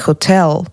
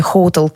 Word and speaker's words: hotel: А там была история, что правильно по-английски говорить hotel, а после песни Hotel California все стали говорить here in the hotel. То есть эта песня, она неграмотно поставила ударение, что на полном серьезе поменяла привычную норму языка.